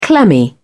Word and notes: clammy: British English

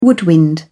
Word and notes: woodwind: British English

